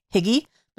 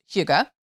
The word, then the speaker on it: hygge: That's hygge, or if you were just seeing it in print, you might think higgy, but it's actually pronounced hygge.